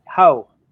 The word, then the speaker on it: Haux: H-A-U-X, that would be how, right? Because the X is silent. – How.